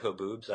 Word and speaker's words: haboob: Because there's a lot of haboobs. I don't know if you know that, like the dust storms and shit. It's like. – Well, how do you say it? – Haboob, it's a H-A-B-O-O-B, and it's just like a dust storm,